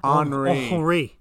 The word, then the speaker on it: Henri: Henri. Right, with a silent H.